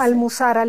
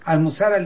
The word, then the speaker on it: Almuzara: Y el día de hoy nos trae a presentar Almuzara, ¿cómo se dice? – Almuzara Libros. – Almuzara Libros. – Sí.